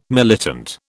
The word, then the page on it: militant: British English